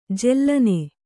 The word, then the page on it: ಜಾಲಿನಿ: jālini